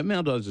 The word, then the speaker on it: Maldives: No, Maldives is fine.